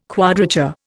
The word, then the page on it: quadrature: Indian